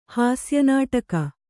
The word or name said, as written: ಹಾಸ್ಯನಾಟಕ